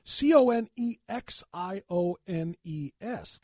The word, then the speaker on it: Conexiones: C O N E X I O N E S, Connections 23.